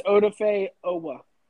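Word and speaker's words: Odafe Oweh: And then we got Odafe Uwe. Ooh, I hope I said that right. – It's Odafe Owa.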